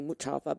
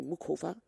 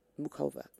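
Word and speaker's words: Muchová: I would say Mukava, but they say Mukova. Mukova. Maybe that's how you pronounce it.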